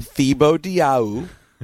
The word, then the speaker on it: Thibodeau: Tom Thibodeau. – Thibodeau, the leading candidate for the New York Knicks coaching job. Had his formal interview with the organization today, sources tell the ESPN. – Tom Thibodeau.